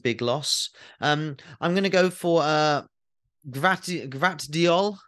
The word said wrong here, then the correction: Gvardiol: Uh, can you help me with the pronunciation? – Yeah, Gvardiol of Croatia.